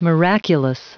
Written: miraculous

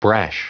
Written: brash

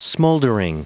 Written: smouldering

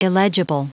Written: illegible

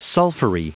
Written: sulphury

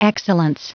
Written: excellence

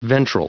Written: ventral

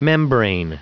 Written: membrane

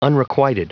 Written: unrequited

